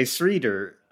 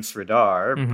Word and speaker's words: Sridhar: So I couldn't pronounce my own name. I would say Sridhar. I was still getting the vowels right. I still wasn't saying Sridhar, but I wasn't pronouncing it correctly or authentically.